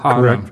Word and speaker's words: haram: that the more that were haram, if I'm pronouncing that haram. – Correct.